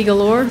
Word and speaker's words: Galore: My name is Heidi Galore. Last name is spelled G-A-L-O-R-E.